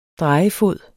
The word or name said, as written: drejefod